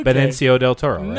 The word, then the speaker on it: Benicio del Toro: Benicio del Toro.